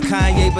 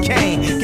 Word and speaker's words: Kanye: Now all I need is y'all to pronounce my name as Kanye, but some of my plastic still say Kane.